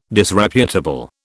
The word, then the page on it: Disreputable: British English